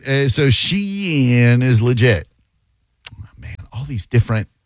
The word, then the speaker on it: Shein: That's how you say it? Shein? Like, Shein. – Yeah, and I'm not going to do the Shein these.